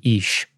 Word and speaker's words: Ish: Remember, so you have Adam, but then the second one would be Ish, is how it's pronounced.